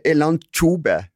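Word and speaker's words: Elantxobe: baskiske språket har noen rare bokstavsammensetninger, blant annet TX, og TX skal vi uttale som CH. Og da blir det Elanchobe som det uttales.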